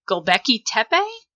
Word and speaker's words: Göbekli Tepe: Göbekli Tepe? – Yeah, good.